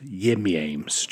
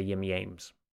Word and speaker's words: Yim Yames: And I believe it's pronounced Yim Yames. – I think you're right. You're right. I meant to say Yim Yames.